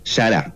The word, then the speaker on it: Yará: Se llama Yará, con Y, acento en la última A, Yará.